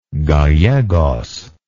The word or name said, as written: Gallegos